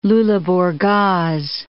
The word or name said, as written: Luleburgaz